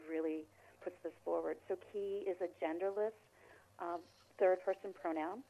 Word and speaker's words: ki: Ki, or perhaps you pronounce it differently, K-I. – I say ki, yeah.